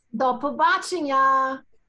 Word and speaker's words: до побачення: How do you say goodbye? – До побачення.